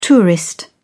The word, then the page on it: tourist: British English